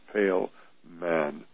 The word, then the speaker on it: Poehlmann: Now, with Dr. Pailman, and his name is pronounced Pailman, those P-O-E-H-L-M-A-N-N, and she has a Ph.D.